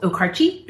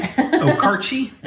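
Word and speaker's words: Okarche: He is from a town here in Oklahoma called Okarchee. – It's one of those that if you read it, you wouldn't say it right. Okarchee, a small town, Oklahoma.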